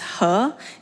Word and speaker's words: He: My name is He.